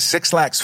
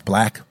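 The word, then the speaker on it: 6LACK: Because Six Lack's first album was so good. – Yeah. – It's actually pronounced Black, but Six Lack.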